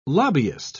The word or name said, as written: lobbyist